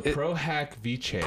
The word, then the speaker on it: pro hac vice: So pro hack Vice. – Pro hack, like pro hacker? – Yes. – It's spelled that way? – P-R-O-H-A-C-V-I-C-E.